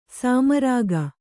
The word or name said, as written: ಸೋಮರಾಗ